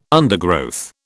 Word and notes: undergrowth: British English

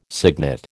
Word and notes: cygnet: American English

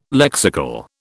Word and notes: lexical: British English

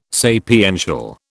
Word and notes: sapiential: British English

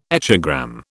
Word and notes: echogram: British English

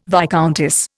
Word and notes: viscountess: Indian

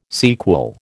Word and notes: sequel: American English

